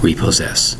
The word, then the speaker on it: repossess: We have three syllables with repossess. Notice the spelling. R-E-P-O-S-S-E-S-S. That's right, four S's in the word repossess.